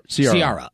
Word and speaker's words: Ciara: Ciara. – Ciara. Okay, yeah. That's how you pronounce it.